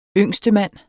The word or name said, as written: yngstemand